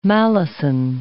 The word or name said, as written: malison